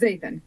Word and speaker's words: Zaethan: Is it Zathian? – It's Zathian, yeah.